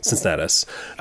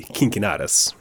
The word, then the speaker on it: Cincinnatus: If you see the letter C in Latin, it's pronounced with the K sound. So this man's name would be Cincinnatus, right?